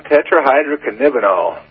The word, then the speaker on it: tetrahydrocannabinol: Well, Delta-9 tetrahydrocannabinol is the way wow.